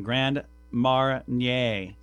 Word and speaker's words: Grand Marnier: I looked up the pronunciation. Grand Marnier. It's French, apparently.